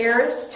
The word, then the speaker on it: aorist: Loved in the Greek aorist tense, I said it right this time, aorist tense means this action occurred in the past.